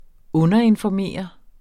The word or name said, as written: underinformere